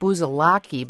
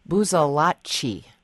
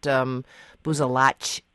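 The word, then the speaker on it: Busalacchi: The Senate did reconfirm DOT Secretary, I want to say Frank Buzalaki, but I was told that you actually properly should pronounce his last name, Buzalacci. But anyway, I'm so used to saying Buzalaki that I probably will continue to do that. He said any good Italian pronounces it Buzalacci.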